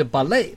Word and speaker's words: ballet: 芭蕾舞这个字从法语变来的，它字尾就不发音，它就 ballet。我们看例句，今晚有芭蕾舞的演出吗？ – Y a-t-il un spectacle de ballet ce soir？ – 你们听到今天晚上呢用的叫 ce soir，那再来演出呢是正 spectacle，这个字超酷的。这个字在英文有类似字，但是把它叫 spectacle。 – Y a-t-il un spectacle de ballet ce soir？